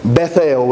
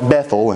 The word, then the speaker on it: Bethel: And there's a reason that he called that place Bethel, which is technically how you're supposed to say it, but we have kind of tightened it together and called it Bethel, and that's just how we pronounce it nowadays.